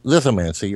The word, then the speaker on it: lithomancy: Oh, I pronounced it lithomancy for a long time until I finally went to a rhyming pronunciation dictionary. Oh, no wonder. – Oh. – Like lithograph, lithomancy.